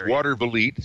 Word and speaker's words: Watervliet: Well, originally it was supposed to go into a place called, I hope I pronounced this right, Watervliet, New York, which was... – Oh, that's it. Oh, that's Watervliet.